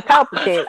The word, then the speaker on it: Palpatine: Well, it's very much he knew he was going to die if he went and shot Palestine there. Not Palestine. Palpatine.